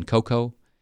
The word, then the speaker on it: cocoa: No, that's another person didn't know you don't pronounce the A in cocoa, C-O-C-O-A, and that limes were not unripe lemons.